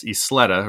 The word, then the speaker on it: Isleta: And I want to make sure I say this right. It's Isleta, right? – Yeah. Yeah. Great job.